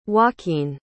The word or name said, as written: joaquine